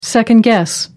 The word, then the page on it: second-guess: American English